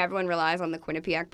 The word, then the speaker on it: Quinnipiac: So I was in Connecticut in college because I went to Quinnipiac.